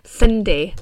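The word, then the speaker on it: Cyndi: And before that, we had Girl Just Wanna Have Fun by Sydney Lauper. So these were both... – Sydney Lauper, yeah. – I cannot pronounce that. I sat before this, I was like, you have to pronounce it right. Cindy.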